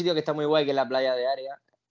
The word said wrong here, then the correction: Area: Area, perdón, siempre lo digo mal,